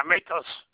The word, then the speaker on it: Matos: Matos, M-A-T-O-S. – M-A-T-O-S? – Yes.